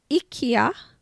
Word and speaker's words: IKEA: And when you're in IKEA, and by the way, can I tell you that my Swedish fiance tells me it's pronounced IKEA.